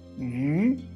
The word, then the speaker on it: 渔: 授人以鱼不如授人以渔。Comme vous pouvez remarquer, le poisson et la pêche, ça se prononce pareil. Ça s'écrit différemment, mais ça se prononce douce, tous les deux. Yu, yu, yu.